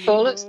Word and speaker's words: Corlett: Yeah, Corlett's fine.